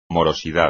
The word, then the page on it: morosidad: espagnol